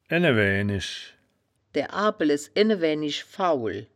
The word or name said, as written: ene-waeaenisch